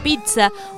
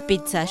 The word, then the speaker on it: pizza: 9 de febrero se celebra el Día Mundial de la Pizza. Uno de los... No es pizza, yo lo dije mal toda mi vida porque es pizza. Pizza, toda mi vida diciéndolo mal.